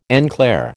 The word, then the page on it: en clair: American English